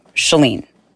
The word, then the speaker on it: Shalene: Shalene. Rhymes with Celine. Celine Dion. Shalene.